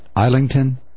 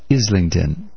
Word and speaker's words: Islington: I pronounced it, what did I call it? Islington? It is pronounced Islington.